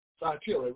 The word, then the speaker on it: Thyatira: And on chapter, let's see, the first church you talked about, Thyatira? – Thyatira, right.